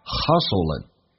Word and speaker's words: hustling: Hustle L 跟 N 形成个连叫 Hustling In a Hustling Bustle of Bustle 跟 of 有一个连叫 Bustle of 记住那个 T 都是不发音的这句话就变成慢速版本 In a Hustling Bustle of Modern Day Life People seldom stop to enjoy the beauty of nature Seldom 很少 Stop to 要理解 alright?